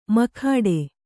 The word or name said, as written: ಮುಕ್ಕೋಟಿ